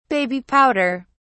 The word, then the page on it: baby powder: British English